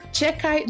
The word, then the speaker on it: Xhekaj: Arbor Chekai, do Montreal Canadiens, fez a sua estreia na liga. O sobrenome dele se soletra X-H-E-K-A-J mas o X não é pronunciado.